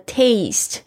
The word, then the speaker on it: taste: to taste experience to the utmost，这个 taste 就是去品味品尝，特别注意一下这个 taste 跟我们平常小考就是 take a test T-E-S-T 跟 taste 有没有发现那个发音的不同？ T-A-S-T-E 是品尝的意思， experience 就是经验体验， to the utmost 就是做到最极限最大最大限度最高点。